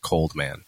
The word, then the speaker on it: Cold X Man: My rap name is Cold X Man, although the X is silent, just pronounced Cold Man.